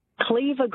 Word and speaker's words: Cleaver: All right, so what is this name that rhymes with weaver, starts with C, and it's a tool? – I'm Charlotte Jane. Cleaver Green.